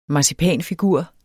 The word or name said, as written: marcipanfigur